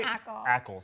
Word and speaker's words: Ackles: That video, a lot of great footage of Jensen Ackles as Soldier Boy. Am I pronouncing his last name correctly? – Soldier Boy? – Ackles. – Ackles, yes.